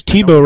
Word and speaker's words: Tebow: Because Tebow rhymes with TiVo.